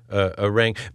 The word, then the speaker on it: orangutan: And how were you introduced to orangutans? By the way, I've known them as orangutans. So correct us on that. – Yeah. It's actually orangutan. Orangutan.